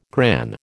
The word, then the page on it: gran: American English